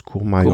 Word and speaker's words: Courmayeur: Ähm, und ab Freitag, spätestens Samstag dann äh, mit der Etappe ähm, von Saint Vincent äh, oder Saint Vincent nach ähm, Courmayeur, wie spricht man das aus? Courmayeur. – Courmayeur.